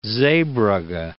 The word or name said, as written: Zeebrugge